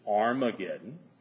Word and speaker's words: Harmageddon: The only thing that signifies is that you pronounce it H. Okay? You pronounce it H. Some manuscripts have the rough breathing mark, some manuscripts don't. So scholars are divided. Is it Armageddon, or is it Harmageddon? If it's Harmageddon, referring to Mount of Megiddo, which there is really no mount, it's more like it was more like a hill.